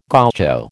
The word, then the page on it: gaucho: American English